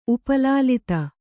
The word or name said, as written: ಉಪಲಾಲಿತ